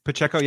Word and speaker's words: Pacheco: Last one I like, Isaiah Pacheco. I think I'm saying his name right. Am I saying that right? – Pacheco, yeah. long day.